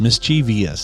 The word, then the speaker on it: mischievous: We're not supposed to pronounce the EE sound in the middle of the word, but the EE sound after the V isn't even there to begin with. Mischievous. Mischievous. – So there's, I'm 0 for 2. – There's not. It's M-I-S-C-H-M-O-U-S-E. No, M-I-S-C-H-I-E-V-O-U-S.